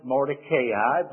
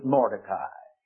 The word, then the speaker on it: Mordecai: Some pronounce it Mordecai, but a good Jew would pronounce it Mordecai.